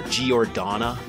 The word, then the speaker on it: Giordana: Hey, you probably heard some cycling friends mispronounce the name of the Italian apparel company as like Giordana or Giordana. Uh, it's Giordana.